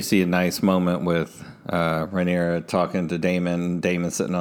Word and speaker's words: Rhaenyra: Yeah, Rhaenyra.